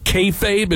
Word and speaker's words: kayfabe: this was not kayfabe.